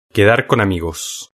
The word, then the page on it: quedar con amigos: European Spanish